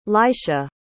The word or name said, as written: Laisha